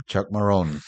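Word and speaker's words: Chuck Marohn: perdóname, ¿cómo se pronuncia? – Chuck Maroon.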